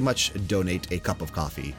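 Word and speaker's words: Ko-fi: It's pronounced coffee, but it's K O F I, like Kofi Kingston.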